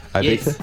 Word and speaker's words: Ibiza: Is that how you say it? Ibiza? – Yes. I just got to Ibiza and I'm ready to party.